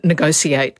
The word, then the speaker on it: negotiate: Well, that's again that, you know, it's a bit like the, it was originally negotiate. That's a spelling pronunciation that's returning, the T-I, that you get in something like nation and you know, becomes a sh. And in negotiate, it's been a sh for a while.